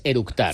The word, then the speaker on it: eructar: y lo primero que hizo es orutar. – Con orutar es eructar. – Sí. ¿Vale? Así dice, ¿cómo se dice? – Eructar. – Eructar. – Sí, con tanta C.